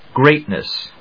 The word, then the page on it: Great Ness: great・ness